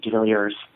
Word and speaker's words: DeVillers: My mother is British and my father is South African. So that's why I pronounce my last name DeVillers.